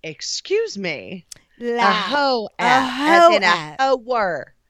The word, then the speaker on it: Heaux: A whore app, yes, like a whore, but it's called Heaux app. It's spelled creatively. It's H-E-A-U-X, and it's a creative way to spell Heaux.